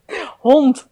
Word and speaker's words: hond: Hond. – Hond. – Could you spell it? – Hond. H-O-N-D.